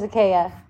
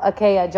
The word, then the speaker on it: Achaia: My middle name is Akea. – Beautiful. – Yeah. So it's like if you go to me on Facebook, I'm Hope Akea Johnson. It's spelled A-C-H-A-I-A.